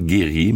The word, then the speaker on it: Gurriers: J'imagine qu'on le prononce comme ça, c'est G-U-R-R-I-E-R-S, donc comme Guerrier, mais sans E, donc Guerrier.